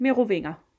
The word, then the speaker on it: Merovinger: Ja, men skal det udtales på dansk eller med fransk? – Du kan godt sige det på dansk. Merovinger.